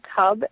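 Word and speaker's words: Kub: It's at Elaine Cub, and Cub is spelled with a K, K-U-B.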